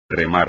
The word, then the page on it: remar: espagnol